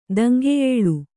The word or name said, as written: ದಂಗೆಯೇಳು